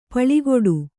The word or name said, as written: ಪಲ್ಕಡಿ